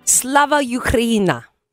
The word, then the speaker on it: Slava Ukraina: It's Slava Ukraina.